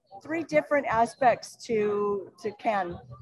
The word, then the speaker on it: Cannes: So Cannes has and I learned the proper pronunciation is to say can. – Yes. – Not con.